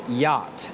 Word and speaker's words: yacht: The next word is yacht This is a difficult word Because it has two letters that are silent. The two letters are C and H.